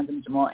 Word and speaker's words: Jimjimo: My name is Sina Jimjimo, and you definitely pronounced my last name correct.